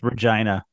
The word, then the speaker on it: Regina: It's actually pronounced Regina. – Of course it is. – I'm telling you the truth. It's pronounced Regina.